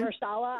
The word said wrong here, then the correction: Mahershala: So say his name again. – It's Mahershala Ali. – Mahershala.